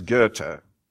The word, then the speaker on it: Goethe: In German, it's Goethe. Pronounce it correctly.